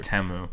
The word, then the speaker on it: Temu: You can get the Timu drone and the Timu grenade. – But then it won't explode. – Yeah, but it's so cheap. You can just buy another one. – It's actually pronounced Temu.